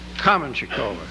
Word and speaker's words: Kamenchikova: Note the pronunciation, Kamenchikova, accent on the first syllable.